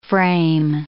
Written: frame

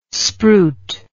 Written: spruit

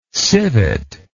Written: civetlike

